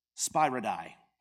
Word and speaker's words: spyridai: But the seven baskets, spyridai. Not Spider-Man. Spyridai. S-P-Y-R-I. D-A-I.